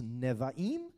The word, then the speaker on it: Nevi'im: They said it's three syllables, Nevi'im.